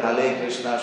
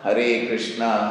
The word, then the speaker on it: Hare Krishna: and therefore that Hale Krishna should become Hare Krishna, Hare Krishna, Krishna Krishna, Hare Hare, Hare Rama, Rama Rama, Hare Hare.